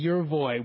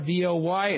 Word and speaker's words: Yervoy: The brand name is a little easy, Yervoy, Yervoy.